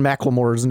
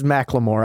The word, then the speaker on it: McLemore: We butchered Mason McLemore's name. It is McLemore. I was wrong when I was like McLemore or whatever I said.